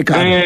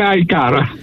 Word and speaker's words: iCaur: Γράφεται iCaur, αλλά για προφανείς λόγους το λέμε iCar. – Θα το λέμε iCar.